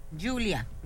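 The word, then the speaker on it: Giulia: Julia. – Julia. – Ah, bueno, pues mira. Se escribe con G. – Por eso dice. Sí, es que es Julia. Es un nombre italiano, como ahí.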